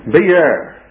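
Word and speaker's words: beer (באר): Beer. That's the Hebrew pronunciation of it, beer.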